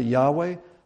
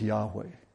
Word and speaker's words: Yahweh: Some pronounce it Yahweh, some pronounce it Jehovah. Jehovah, the pronunciation Jehovah really came as a result of a clerical error, a scribe's error. Don't tell the Jehovah's Witnesses that, but probably most likely is pronounced Yahweh.